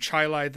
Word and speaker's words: Chili: We have a city named Chilai that's spelled Chili.